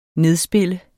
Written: nedspille

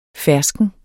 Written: Ferskner

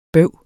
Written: bøvs

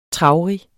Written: traurig

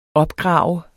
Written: opgrave

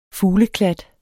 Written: fugleklat

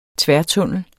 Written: tværtunnel

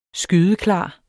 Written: skydeklar

